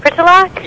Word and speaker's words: Pritulak: L-A-K. – L-A-K? – Right. – And that's pronounced Pritulak? – Yeah.